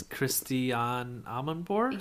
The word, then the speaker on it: Christiane Amanpour: It's Christiane Amanpour? – Yeah, there you go.